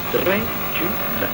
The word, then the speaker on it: Dracula: Dracula. The sound is so intriguing. Three syllables. Dracula.